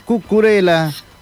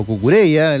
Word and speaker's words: Cucurella: Marc Cucurella, o Cucurella, como le pronuncian en España, gran valor, y además muy vistoso.